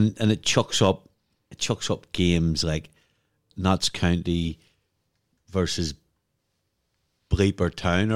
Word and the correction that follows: Belper Town: Belper Town.